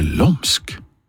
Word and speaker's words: Lom: Eller du, kanskje lomsk? Kanskje skal navnet på det her bandet uttales lomsk? De kommer jo fra Lom i Gudbrandsdalen, som jeg har lært skal uttales lom.